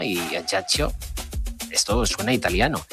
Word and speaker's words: Ajaccio: Bueno, ellos lo pronuncian, ¿qué tal, antes de que nada? Lo pronuncian Ajaccio.